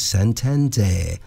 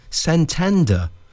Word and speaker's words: Santander: is Santander, as generally pronounced in the UK, or Santander, generally pronounced in Spanish.